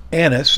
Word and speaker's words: Annas: Annas, and I mispronounced his name a couple times yesterday. I did finally get it right, but I said Ananias, which I think there's a couple of Ananiases.